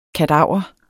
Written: kadaver

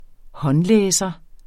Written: håndlæser

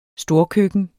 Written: storkøkken

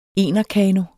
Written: enerkano

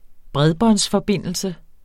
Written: bredbåndsforbindelse

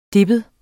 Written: deppet

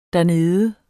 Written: dernede

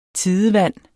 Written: TIDEVANDES

